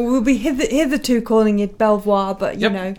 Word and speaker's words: Belvoir: So that's from Now, I think we call it Belvoir, but last time we pronounced it Belvoir when we were doing the Craft Gin Club video, we got some shtick because it's actually beaver or something. – Belvoir? Look, it's spelled B-E-L-V-O-I-R. It's Belvoir.